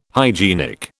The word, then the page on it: hygienic: British English